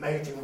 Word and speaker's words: Machen: M-A-C-H-E-N? – Yes. – Oh, I'm probably mispronouncing it. Yes. – That's the way I've always heard it pronounced.